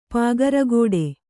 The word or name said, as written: ಪಾಗರಗೋಡೆ